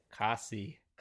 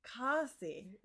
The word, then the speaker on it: Cosi: Cossie. – Cossie. – Yeah. – Cossie. – Ah, you gave it the like, okay. – Yeah. – I gave it the long O, you gave it the short O.